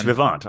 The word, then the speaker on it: Vivant: I confirmed that pronunciation. – Oh, you did. It's Vivant.